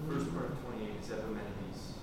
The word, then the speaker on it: Epimenides: Epimenides.